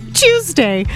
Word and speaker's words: Tuesday: I almost say as if the word is like chew, C-H-E-W, Tuesday.